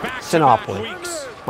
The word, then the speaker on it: Sinopoli: No, it is Sinopoli. As soon as I read it, I knew, wait a minute, that's not right.